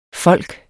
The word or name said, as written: folk